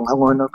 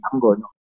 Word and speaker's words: Angono: Angono or Angono? Ganun po yung pag-pronounce sa kanya. Pwede pong Angono, pwede Angono.